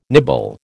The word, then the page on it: nibble: American English